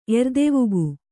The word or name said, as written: ಎರ್ದೆವುಗು